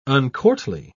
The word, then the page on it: uncourtly: British English